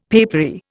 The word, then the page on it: papery: Indian